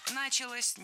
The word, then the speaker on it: началось: И вот именно в тот момент в Голландии, если я правильно понимаю, началось развитие вот именно... – Началось неверно. Вот здесь уже ударным становится окончание. Началось. Дело в том, что возвратная часть «се» во многих словах как бы притягивает ударение к себе.